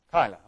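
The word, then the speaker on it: Keilah: Would you pronounce that for me? Kyla. Okay, I said it right.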